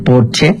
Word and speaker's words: Porsche: O decir que tengo un Porsche en lugar de decir tengo un Porsche, porque en alemán, Porsche, se dice Porsche porque todas las S al final se pronuncian como Marlene Dietrich, por ejemplo, que todo el mundo dice Marlene, pero es Marlene.